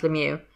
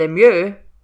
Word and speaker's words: Lemieux: And then I came to Mario's last name, and I had to stop again to check how to pronounce Lemieux, because the 16-year-old in me that learned French wanted to go with Lemieux, but any time I read the name, I pronounce it differently in my head.